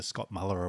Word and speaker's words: Muller: This is, of course, the Scott Moeller Award, or as we pronounce it in Australia, the Scott Muller Award.